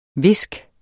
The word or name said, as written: viske